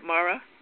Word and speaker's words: Marah: Tell us a little bit about the lion named Mara, M-A-R-A-H. Is that the way you pronounce it, Mara? – It is, Mara, and she is the mother of Ra, is what it means.